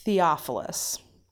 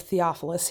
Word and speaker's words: Theophilus: So it looks like the letters are T-H-E-O-P-H-I-L-U-S. – Nope. – Right? So we're going to call him Theo, but I think it's actually pronounced Theophilus. – Okay. – Greek. I think so.